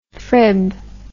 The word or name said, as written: frib